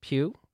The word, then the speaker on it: Pugh: Pugh.